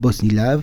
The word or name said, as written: bosnilav